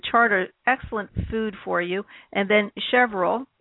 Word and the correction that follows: chervil: I don't know if that's pronounced correctly, which is a companion to radishes for improved chervil. Yeah. – Chervil. And it sounds like a gerbil. So it rhymes. We could create a poem.